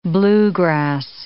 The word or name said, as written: bluegrass